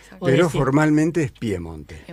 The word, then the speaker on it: Piemonte: Claro, por eso no es Pia, sino que es Pie. – Piemonte, claro. – El nombre formal. Lo que pasa que pronunciado en piemontés es una E abierta. Entonces parece que dijeran Piamonte y, bueno, hay un conflicto larguísimo.